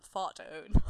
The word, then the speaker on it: Fartown: That's just the road name, Fart Town or Fart Own. – I will pronounce it in the correct way, which is Fart Own.